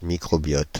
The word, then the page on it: microbiote: France (Paris); /mi.kʁɔ.biɔt/